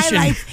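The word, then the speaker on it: Chi-Lites: Shy Lights. C-H-I, youngin', as in Chicago, obviously.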